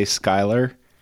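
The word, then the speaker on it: Schuyler: I think the fact that we know how to say Skylar is probably, we earned some points for that.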